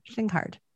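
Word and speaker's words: Slijngaard: I'm trying to go with Schlinghard. I think that's how you say it. – Schlinghard. – Yeah, Schlinghard. I think that's a Dutch name.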